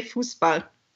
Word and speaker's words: Fußball: So in Dutch you actually say football, and in German you say Fußball. – Fußball.